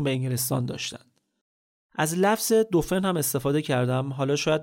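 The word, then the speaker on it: Dauphin: به خاطر همین بهش میگفتن دوفن، که تلفظ فرانسوی دلفین هم هست ظاهرا.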